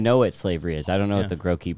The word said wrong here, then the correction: Gracchi: brothers are. – Gracchi. – Gracchi.